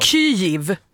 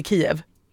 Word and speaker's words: Kyjiv: Kyiv. Kiv. Men det stavas K-Y-J-I-V. – Men det ska uttalas Kiv. – Kiv, som kniv. Absolut inte Kiev.